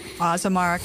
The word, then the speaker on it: Azamara: And we'd like to welcome to the program from Azamara Club Cruises. Now, did I say it right? – You did. Azamara Club Cruises.